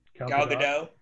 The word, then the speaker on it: Gadot: Gal Gadot.